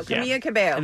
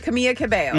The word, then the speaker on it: Camila Cabello: Camila Cabello. – I think you say it right. – Camila Cabello. – Mm-hmm. – Camila Cabello. – Well done.